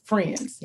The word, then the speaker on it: Phrinds: She is a communications professional and is also the co-founder of the Friends, pronounced, well, it's spelled P-H, which I love, P-H-R-I-N-D-S, but it's pronounced Friends.